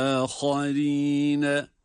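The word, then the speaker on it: aharine (وآخرين): Bakınız ve, ve, ve, ve aharine, ha da burada kalın, ra ince, evet, ha kalın, ra ince sesle okunacak.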